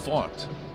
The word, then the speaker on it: Pfaadt: So P-F-A-A-D-T. And it's pronounced fought, as in I fought the law and the law won.